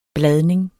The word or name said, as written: bladning